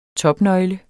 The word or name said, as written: topnøgle